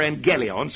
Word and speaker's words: Euangelion: Euangelion or Angelion. Some pronounce the G a bit different.